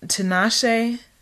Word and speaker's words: Tinashe: Und ansonsten das Album von Tinashe. Wird es so ausgesprochen? – Ja. – Nein, sie hat letztens was. Egal. Tinashe, basically, fand ich auch richtig gut, das neue Album 333.